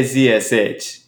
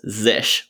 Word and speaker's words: zsh: I say Z-S-H. You say Zish? – I've heard other people say Zish. Maybe Z-S-H is another one. Because it's like Z-S-H has three syllables. Zish, one syllable. He's the most elegant to me. – Okay, I like it. It's closest. – So I think it's like fish.